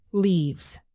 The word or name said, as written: leaves